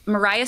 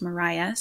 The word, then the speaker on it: Maria: Mariah Stewart. It's spelled like Maria, but I learned from Jasmine Holmes that it's pronounced Mariah Stewart.